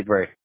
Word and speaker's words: Bray: Eddie Bray. – B-R-A-Y. B-R-A-Y.